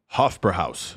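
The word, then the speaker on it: Hofbräuhaus: Hofbräu Haus.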